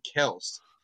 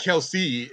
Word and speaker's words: Kelce: There was actually a report that came out that Travis Kelce's dad said that the way that you pronounce it is actually Kels. It's Kels. It's not Kelce, it's Kels.